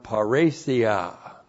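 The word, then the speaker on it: parrhesia: And the word confidence is the Greek word paresia. Looks like that in the Greek, P-A-R-R, long E-S-I-A, paresia.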